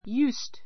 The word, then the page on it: used: júːst; ユースト